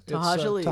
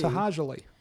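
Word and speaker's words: To'hajiilee: Tahajali. – Tahajali is the name.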